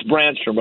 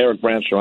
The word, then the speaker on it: Brännström: But I do think, and it's Brännström, by the way, Eric Brännström, I was getting the name wrong.